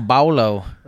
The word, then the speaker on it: Paulo: That someone named Paulo, spelt Paul with an O on the end, can be Baolo. However you're supposed to pronounce it. – Junior Paulo. – No, they say with a B. Paulo. It's like a B, like Baolo.